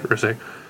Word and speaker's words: Rizik: It's my fault for not making it clear, but the beginning is pronounced like it would be in River, not Rock. Oh, yeah. Can I say Rizik?